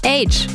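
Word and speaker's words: Aitch: Daher aber auch sein Künstlername Age, weil das H vorne in Harrison, also der Buchstabe, wird englisch ja Age ausgesprochen.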